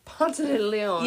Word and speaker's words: Ponce de Leon: Because all I imagine was Ponce de Leon. – Yes, I said it correctly. Because then she goes, and how the white people say it.